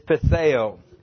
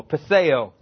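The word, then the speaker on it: peithō: The Greek word is petheo. It is P-E-I-T-H-O. P-E-I-T-H, long O, petheo.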